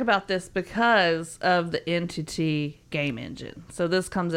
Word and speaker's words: NTT: They were also promised, oh, this really cool brand new gaming engine they were going to be using called NTT, pronounced Entity.